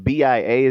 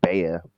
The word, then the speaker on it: B.I.A.: Oh, and B.I.A. is pronounced Bia, like the Little John song, Bia, Bia. Like, it's, I think you were saying Bea, but yeah, I think it's pronounced Bia.